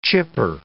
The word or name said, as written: chipper